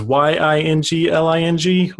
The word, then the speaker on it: Yingling: My website is jasonyingling.me, and then my Twitter is jason_yingling, and that's the Yingling is Yingling, which is sounds like the beer, but it is spelled differently.